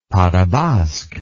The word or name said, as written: pas de basque